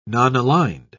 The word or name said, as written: nonaligned